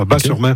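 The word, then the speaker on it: Batz-sur-Mer: On prononce Bas, le T ouais, ne se prononce pas, Bas-sur-Mer. Okay.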